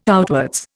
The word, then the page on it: outwards: Indian